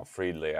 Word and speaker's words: Friedli: Oh, Friedley.